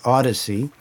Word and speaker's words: Audeze: I haven't yet investigated it, but I recently added to my collection they're pronounced Audacy, and they're spelled AUDEZE, and the model that I chose is the LCDX.